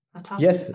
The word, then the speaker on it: autophagy: Autophagy. Yes.